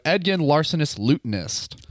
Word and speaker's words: Edgin, Larcenous Lutenist: Edgin, Larcenous Lutenist.